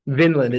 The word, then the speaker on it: Vinland: I think all our fellow anime enthusiasts listening would probably understand what you're talking about and agree with you about Finland. – Vinland, it's spelled a V. It's weird, I know.